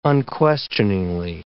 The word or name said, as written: unquestioningly